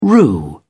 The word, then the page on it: rue: British English